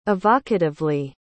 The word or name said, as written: evocatively